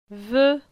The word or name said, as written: Ve